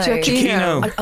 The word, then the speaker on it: Giacchino: How do you pronounce his name? – Giacchino. – Giacchino.